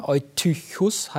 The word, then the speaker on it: Eutychus: Und zwar einen Namen, der heißt Eutychos oder Eutychus ausgesprochen. Und ich habe natürlich dann gleich diese Textstelle aufgeschlagen. Eutychus heißt ja dann übersetzt der Glückliche.